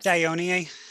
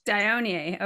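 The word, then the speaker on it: Dionaea: Dionea, is that how you say it? I don't know how you say it. How do you say it? – Yeah. – Venus flytraps. – Dionea. – Dionea.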